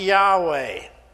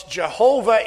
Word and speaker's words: Yahweh: See, in Hebrew, when you say I am, it sounds like Yahweh. Folks, Jehovah is a mispronunciation.